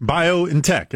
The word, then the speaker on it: BioNTech: B-I-O, bio, then N-N, then tech. BioNTech. Okay. I don't know how you pronounce it. – BioNTech. It's like a shop and save.